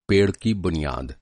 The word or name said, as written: बुनियाद